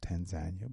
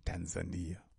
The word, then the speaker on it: Tanzania: It is pronounced Tanzania, but most people pronounce it Tanzania.